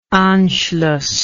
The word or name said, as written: anschluss